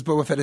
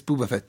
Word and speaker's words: Boba Fett: Vincent Cassel, you know, he mispronounces Boba Fett as Booba Fett.